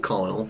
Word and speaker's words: Colonel: Colonel. Why do I keep calling him Colonel? – Well, it's spelled Colonel, but just pronounced Colonel.